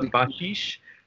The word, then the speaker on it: Bašić: And so there was an architect whose name Ksenia taught me how to pronounce, named Nikola Bašić.